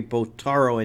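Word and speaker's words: Bottaro: The Mindful Catholic by Dr. Gregory Botaro, and his name is spelled B-O-T-T-A-R-O, B-O-T-T-A-R-O.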